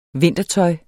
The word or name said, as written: vintertøj